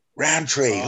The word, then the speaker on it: Rowntree: Rowntree, like the sweets.